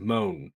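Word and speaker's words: Moné: It's Moan. It is honestly just Moan. Not within, you know, I'm not trying to say Moan, M-O-A-N, it's just Moan. It's like, there's no T to add to Monè.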